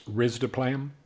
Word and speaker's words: risdiplam: Another drug, which is called, I'm probably going to butcher this, the pronunciation here, but I'm going to say risdiplam. Is that, I don't know. – It's actually pronounced risdiplam. – Risdiplam.